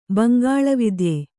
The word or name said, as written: ಬಂಗಾಳವಿದ್ಯೆ